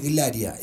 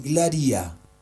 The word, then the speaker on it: Glaria: No es Glaria, es Glaria. – Glaria. Ah, la verdad, porque le metes el acento en la I.